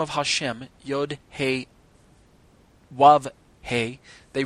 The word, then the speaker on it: Hashem: At other times it should be pronounced, the Stone Edition goes on to say, at other times it should be pronounced Hashem, literally the name.